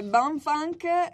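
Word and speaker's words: Bomfunk MC's: Allora, questo è Bonfunk MCs.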